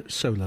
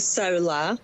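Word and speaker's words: saola: What does the sola look like? – Hello, hello, nice to be with you today. So it's the Sola, just the pronunciation, it's quite tricky for people who aren't from Lao.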